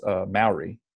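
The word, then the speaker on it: Māori: I didn't want to interrupt you guys, but I'm pretty sure it's pronounced Maori. It's Maori? Maori.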